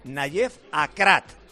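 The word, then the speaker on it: Aguerd: Luego os voy a contar, porque tenemos un amigo marroquí que nos ha dicho que no se pronuncia Huert, que se pronuncia Nayef Akrat.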